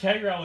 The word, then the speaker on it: Cairo: Now, yes, I know it looks like Cairo and it's pronounced Cairo in most places, but we dare to be different here in Henderson County. So swing by Cairo and Smith Mills sometime if you haven't already done so.